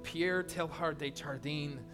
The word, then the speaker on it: Pierre Teilhard de Chardin: It's this guy, I can't even say his name, Pierre Teilhard de Chardin. That's the right way to say it, I promise.